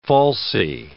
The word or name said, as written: falsie